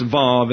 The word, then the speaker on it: Vav: The Hebrew He, or Het, is the H, while the Wow is pronounced Vav in the English and therefore comes across often as a V.